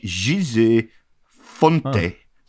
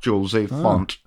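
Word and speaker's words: José Fonte: He also revealed that we've all been saying his, and more importantly, Jose Mourinho's name wrong. It was sort of like Gise Fonte is his name, not Jose Font.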